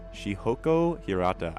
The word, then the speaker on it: Shihoko Hirata: You just heard Time to Make History by Shihoko Hirata.